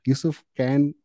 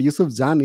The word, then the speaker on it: Yusuf Can: But Yusuf Can is how you pronounce it if you're from Turkey. So meet Yusuf Can.